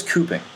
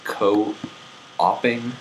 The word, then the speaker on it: cooping: So what is cooping? – Wait, isn't that pronounced co-oping? – No, there would be a dash. It's called cooping.